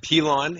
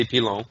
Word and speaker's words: Pilon: Pilon is the most accepted way. If you want to get kind of French-Canadian, you could say Pilon. But Pilon is the preferred over Pilon, Pilan, and all the other weird ones I get.